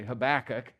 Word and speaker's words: Habakkuk: I had a really good introduction giving you the sort of overview of the book of Habakkuk, which is how it's pronounced, by the way, Habakkuk, which is all about that, but it was three slides long and I had to cut.